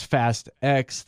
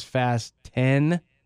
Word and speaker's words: Fast X: But they don't want you to pronounce it as Fast X. They want it to be pronounced as Fast 10.